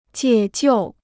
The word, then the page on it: 解救: jiě jiù